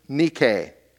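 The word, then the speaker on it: nike (νίκη): It's the word nike. poorly pronounced, Nike. Nike, yeah, the shoe company.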